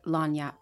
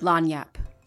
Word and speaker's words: Lagniappe: The correct pronunciation is Lagniappe. – Oh, you nigga. – Lagniappe? – Never would have got that. – Lagniappe.